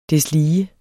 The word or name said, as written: deslige